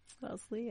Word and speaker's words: Wellesley: Wellesley? – Wellesley, yeah.